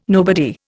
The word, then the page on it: nobody: Indian